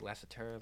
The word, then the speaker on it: Lassiter: How do you say Lassiter? – Lassiter, yeah.